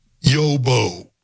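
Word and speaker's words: Xu Bo: The intended father is Yobo. Yeah, just, it's a Chinese name. X-U, which is pronounced U, and B-O, which I guess is Bo. Yobo.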